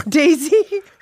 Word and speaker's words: dais: D-A-I-S. – Dais is Dais. – Correct.